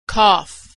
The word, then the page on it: cough: gh